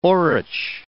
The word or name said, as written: orache